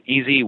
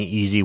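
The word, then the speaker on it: EZ: Now, for people who want to kind of look into this a little bit more, when you're saying easy water, are you like, have you written about it in literature at all? Is it like E like elephant, Z like zebra water? Is that how you're pronouncing it?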